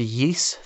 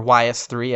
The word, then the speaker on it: Ys: Another one of my favorite soundtracks for the Sega Genesis is the soundtrack to Yeast 3, or YS3, as I always incorrectly called it growing up, because I had no idea how to pronounce it. – I said it that way too. – It is Yeast, right? Is that? – I believe so. It's like Yeast, based on the Japanese characters.